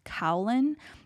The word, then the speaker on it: kaolin: I don't know what the molecular makeup is, but I do know like the main ingredient in porcelain is kaolin. That's spelled K-A-O-L-I-N.